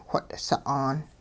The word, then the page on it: whot' sa'aan: hupa